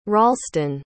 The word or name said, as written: Ralston